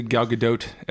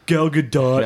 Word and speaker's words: Gadot: Let's get to Gal Gadot as Wonder Woman. Because, by the way, the T is pronounced.